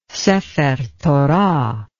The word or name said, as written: sefer torah